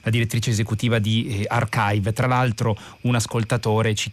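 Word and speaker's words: arXiv: Magari Eleonora Presani ci spieghi perché si scrive archive, si pronuncia archive ma è scritto con la X, con una X grande. – Sì, in, in, realtà è una X quando si scrive sul computer, però è una key greca, ecco. per cui eh, l'idea è di leggerla come una key e quindi si legge archive, è un, è un modo eh, più breve per scrivere key.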